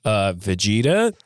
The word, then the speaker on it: Vegeta: Vegeta is a character. – Ooh, you even said it right.